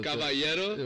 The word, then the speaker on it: Caballero: Me how to roll my L's or just to pronounce L's in Spanish. Caballero. – With the Y.